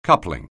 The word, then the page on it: coupling: anglais